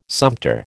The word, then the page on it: sumpter: American English